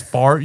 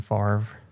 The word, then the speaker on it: Favre: There's definitely no V after the R. – Yeah. – So why do they pronounce it that way? – I don't know. – Favre. – Favre. – No. – Favre. – No. – Favre. Brett Favre. – It's like the R silent.